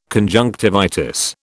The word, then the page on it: conjunctivitis: British English